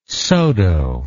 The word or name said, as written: Saulteaux